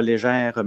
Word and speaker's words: Léger: Puis dans la région ici, on dit la même chose, là, légère, même si c'est écrit, il n'y a pas de E à la fin. Même si c'est écrit léger, c'est pour ça qu'il y a beaucoup de gens d'ailleurs qui prononcent léger, mais c'est nous autres, on a tout le temps prononcé légère.